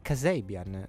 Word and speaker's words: Kasabian: Allora, giustamente il mio regista, nostro regista, però mio soprattutto, mi ha fatto notare che ha scoperto nella pausa che si pronuncia Kasabian, no? Kasabian.